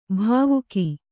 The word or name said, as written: ಭಾವುಕ